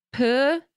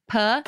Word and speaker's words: Pugh: Also, Florence Pugh. – Oh. – How do you say her last name? Pugh? – Pug. – Puh. – Puh. – Puh. – I think, right? You just like kind of whisper.